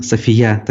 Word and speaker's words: София: А, у турков София пишет, София, точнее, с ударением на букву «Я», пишет нам Рита.